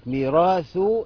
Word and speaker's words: ميراث: خلي بالك ميراث هنخرج فيها اللسان وبعدها في النطق مباشرة السين المشددة بتاع السماوات يبقى دي ثاء ودي سين ميراث السماوات والأرض يعني الله عز وجل له ميراث السماوات والأرض.